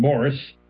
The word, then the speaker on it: Maurice: We are talking about the Brothers Gibb, Barry, Robin, and Morris. Even though it's called Maurice, he pronounced it Morris.